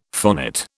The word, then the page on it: phonate: British English